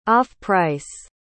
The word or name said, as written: off-price